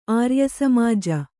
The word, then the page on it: ಆರ್ಯಸಮಾಜ: āryasamāja